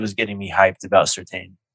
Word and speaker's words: Surtain: Gun to your head, him or Surtain? Surtain, sorry.